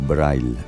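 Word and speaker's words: Breil: Joseph Karl Braille, o Braille, pronunciado a la alemana, considerado uno de los primeros profesionales de bandas sonoras.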